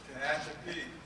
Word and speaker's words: Tehachapi: First H&I meeting was held at, I can't pronounce it, Tehachapi, whatever. – Tehachapi. – See, somebody was in that prison. They know how to say that.